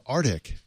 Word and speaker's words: Artik: The new hardware will be called Arctic, according to CNET. That's spelled A-R-T-I-K.